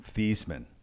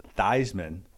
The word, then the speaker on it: Theismann: Joe Theismann's actual name is Joe Theismann. – Interesting. – He was encouraged to change it to be pronounced Theismann. Why do you think? – Because he was an attractive guy back then. Theismann for the Heisman.